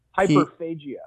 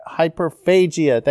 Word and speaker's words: hyperphagia: Hyperphagia. Hyperphagia.